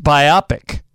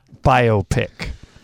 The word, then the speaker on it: biopic: Using the compound stress rule, the word is pronounced biopic, not biopic.